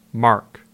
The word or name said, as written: Mark